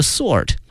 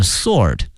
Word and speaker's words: sword: The Book and the Sword。同时啊同学们注意一下这个剑哈它英文的发音啊它看这个拼写里面有一个 W, 但是呢它的发音呢是 sword, 是 sword, 所以同学们要注意一下。